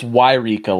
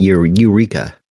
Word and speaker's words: Yreka: Is it Yreka or Eureka? Because I know some people are like, it's Eureka. – No, it's Yreka. Like, it's spelled Y-R-E-K-A.